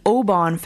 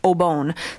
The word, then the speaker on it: Obon: The Japanese celebrate the Obon Festival, also known as Matsuri or Urabon, and it's pronounced Obon, so I totally mispronounced that.